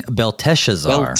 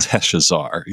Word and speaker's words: Belteshazzar: Right, so I've been practicing Belteshazzar. – Belteshazzar, yes.